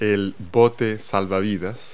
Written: lifeboat

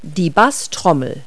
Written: bass drum

